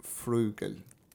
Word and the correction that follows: Vogel: Frugel. Vogel. – Vogel. Oh, Vogel.